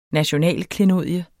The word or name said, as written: nationalklenodie